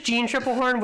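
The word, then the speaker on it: Jeanne Tripplehorn: It's just Jean Triplehorn. I think you said Jeanne earlier, and then I think you said Janine here, but it's just Jean Triplehorn with a bunch of extra letters.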